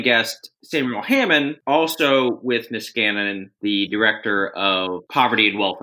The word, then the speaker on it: Niskanen: Is this Niskanen Center. – Niskanen Center, yes.